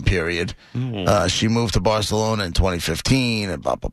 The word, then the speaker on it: Barcelona: My Spanish teacher in college was from Spain, and she said she would say Barcelona. – Barcelona. – Right. – Say it right, lady. It's Barcelona.